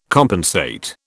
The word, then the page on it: compensate: British English